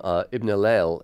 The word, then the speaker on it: Ibn Hillel: You know, it's delivered in a really, I mean, this album, Ibn Hillel, am I saying that right? – Yeah, Ibn Hillel? – Totally.